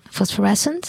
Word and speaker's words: Phosphorescent: Phosphorescent? – Phosphorescent. – Phosphorescent. – Yes, phosphorescent. – Yep. – It's called what? – It's Hey, Me, I'm Light. – There you go. By Phosphorescent.